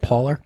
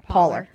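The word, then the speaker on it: Pahler: I think it's P-A-H-L-E-R, right? – Yeah. How would you say that? – Pauler. – Pauler. That's it. Pauler.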